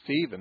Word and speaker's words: Stephen: Just like my name is Stephen, that's the English pronunciation.